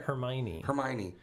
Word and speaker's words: Hermione: I'd rather meet Hamali. – Hamali? – That's her name, right? Hamali. – Get the name right. – Hamali, right? – Hermione. I thought it was Hermione for the longest time when I was reading it before I even heard it.